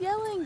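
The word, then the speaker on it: Jelling: Dad, it's yelling, Dad. – What do you mean? It's gelling. It's pronounced... It's got a J on it. – But that's not normal. You say Y for Germanic languages.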